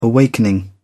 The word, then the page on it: awakening: British English